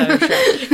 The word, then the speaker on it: Schreck: Yeah, so Shrek. It's spelt with a C-H. – Yeah. – but take the C out, it's Shrek.